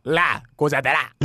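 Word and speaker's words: La Gozadera: 라 고자데라.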